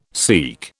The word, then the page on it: Sikh: British English